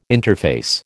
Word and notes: interface: American English

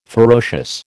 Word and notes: ferocious: American English

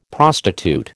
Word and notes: prostitute: American English

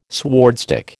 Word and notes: swordstick: American English

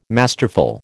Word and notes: masterful: American English

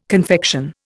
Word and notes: confection: Indian

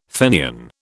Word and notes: Fenian: British English

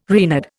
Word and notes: Reynard: Indian

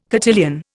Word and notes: cotillion: Indian

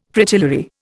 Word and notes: Fritillary: Indian

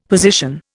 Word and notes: position: Indian

